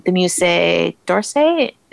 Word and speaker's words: Musée d'Orsay: You can tour the Anne Frank House in Amsterdam, the Acropolis Museum in Athens, the Musée d'Orsay.